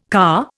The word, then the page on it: car: Indian